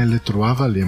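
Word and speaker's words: Les Trois Vallées: Como é que fala Les Tres Valles? Em... Primeiro deve ser Les Trois Valles, né? – É, Les Trois Valles, muito bem.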